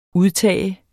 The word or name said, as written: udtag